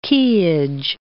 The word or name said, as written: quayage